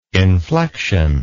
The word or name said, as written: inflexion